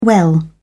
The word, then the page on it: well: British English